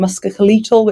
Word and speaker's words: musculoskeletal: So that's on areas like mental health and musculoskeletal, which I can never pronounce.